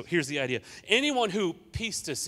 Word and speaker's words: pistis: Okay, so listen, that word "believe" right there in the original language, it's the word pistis. Everybody say pistis. No, come on, you can do it better than that. It's Greek, so you got to say it like that.